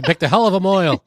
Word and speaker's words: mohel: It is when the Mohel Mahu, how do you say Moil. It? – Moil. Okay, Viola. Holy shit.